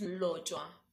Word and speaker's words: Mlotshwa: And how do you pronounce it? – It's Lojwa.